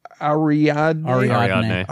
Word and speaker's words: Ariadne: Ariadne.